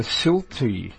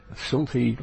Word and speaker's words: Silte: Program in the Silti language, that's spelled S-I-L-T-E, Silti language, signing on at 1730.